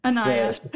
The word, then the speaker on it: Onaya: I'm sorry, I said it wrong again. Onaya.